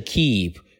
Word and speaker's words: keep: 第一个呢是 keep，是一个长元音，长元音的发音要点是长而且饱满。 keep，keep，读的长一点就 OK 了，对不对？